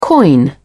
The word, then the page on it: coin: British English